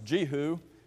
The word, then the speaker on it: Jehu: it's properly pronounced Jehu. I think we usually say Jehu, so I'll probably say both as a part of this class to make all of us feel good.